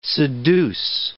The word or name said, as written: seduce